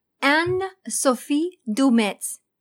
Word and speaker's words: Anne-Sophie Dumetz: Anne-Sophie Dumetz. Did I say it right? – Yeah, that's good.